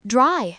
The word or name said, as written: dry